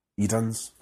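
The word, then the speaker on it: Idun's: Edens. – Edens. Even though it's spelled I-D-U-N apostrophe S.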